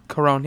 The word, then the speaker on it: Alfonso Cuarón: I would love an Alex and Ada movie to be directed by Alfonso Cuarón. – Cuarón, yeah, you're pretty close.